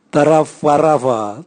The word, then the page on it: taravwaravwa: Raga